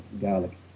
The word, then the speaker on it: Gaelic: Gaelic is more of the pronunciation in Irish, but in Scottish it's sort of Gaelic, almost sounds like garlic.